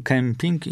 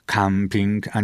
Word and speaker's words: kemping: Bo tak, wyraz się ortograficznie i fonetycznie, czyli wymawianiowo, dawno spolonizował, czyli powtórzę, piszemy kemping, mówimy kemping, a czasami jak ktoś napisze camping, to ma ochotę też mówić camping, a nie kemping, czyli tak trochę udając angielszczyznę.